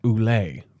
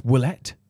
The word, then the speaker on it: Ouellet: Because it's like every time it's like everybody says Ouellet, but it's Ouellet. – Ouellet. – It's Ouellet. – Ouellet. – Which you just nailed there, and it is. It's Ouellet.